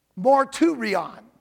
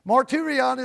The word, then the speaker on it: martyrion: Witness comes from the Greek word marturion. Marturion. Marturion is spelled M-A-R-T-Y-R-I-O-N. Mar, but it's pronounced marturion.